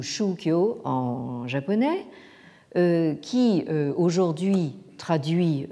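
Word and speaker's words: shukyo (宗教): La complication vient du fait que c'est ce terme de jiao qui a été utilisé pour former un néologisme en japonais, le japonais donc shukyo, prononcé en chinois donc zongjiao.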